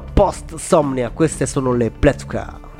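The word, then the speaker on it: pletyka: Sono atmosfere piuttosto inquietanti quelle evocate da questo trio, che si traduce come pettegolezzo, pletvka, questo significa in ungherese, non lo sapevate? Io sì, è scritto pletica, però ho scoperto che la y in ungherese si pronuncia come v.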